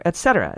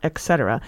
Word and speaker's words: et cetera: Now, another, et cetera. It's pronounced as it's spelled, not et cetera.